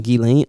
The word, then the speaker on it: Ghislaine: Ghislaine. – Okay. I was like reading it, but hearing them say it, and I was just having a stroke in my head. – Ghislaine Maxwell, Jeffrey Epstein coerced over 300 girls for pretty much for his sexual pleasure.